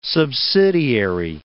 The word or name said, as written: subsidiary